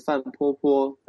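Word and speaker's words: Fan Popo: In Chinese, Fan Po Po. In English, it's Po Po Fan.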